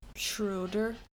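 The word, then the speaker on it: Schröder: So if you saw this name but with an umlaut over the O, how would you say that? – Schroeder.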